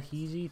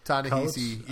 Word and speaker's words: Ta-Nehisi: Tana, how do you say it? Tana? Tanahizi. – Ta-Nehisi.